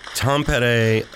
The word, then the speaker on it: Tampere: They're going crazy in Tampere. – Yeah, and Carlos says we are actually pronouncing it correctly. – That's miraculous. Tampere, Finland.